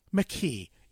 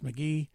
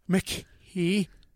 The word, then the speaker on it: McKee: Without waiting a second, our special guest today, Mr. Max McKee. And it's not pronounced McGee. – No, it is not.